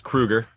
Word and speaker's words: Kroeger: It's Kroeger. Don't trust the internet.